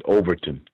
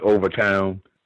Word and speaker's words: Overtown: I think I heard you, Gus, mention something about, you pronounced it Overton, but it's properly known as Overtown.